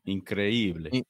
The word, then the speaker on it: Increíble: Increíble. – Is that how you say it in Spanish, bro? – That's how you say it. Increíble.